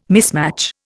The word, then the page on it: mismatch: Indian